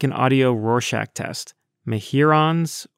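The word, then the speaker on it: Mehuron's: And so, me-hyur-ons it is. Me-hyur-on, as in Lake Huron.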